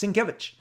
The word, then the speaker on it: Sienkiewicz: Again, Bill, when he came out, fans would go, Bill Sienkiewicz? How do you pronounce that? And I remember at a convention, he's like, it's Sienkiewicz. And once you're told by the man how to say his name, Sienkiewicz, boom.